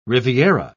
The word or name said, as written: riviera